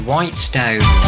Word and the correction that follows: Whetstone: I mispronounced that, but it is Whetstone.